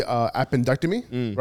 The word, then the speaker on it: appendectomy: Like last year, he had the appendectomy, right?